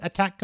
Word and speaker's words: ATAC: The ATAC, Automatic Targeting Attack Computer. – I think it's pronounced attack, Dave.